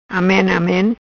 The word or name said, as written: Amen amen